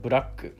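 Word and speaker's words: buraku (ブラック): But nevertheless, we use the word black, which is pronounced as buraku.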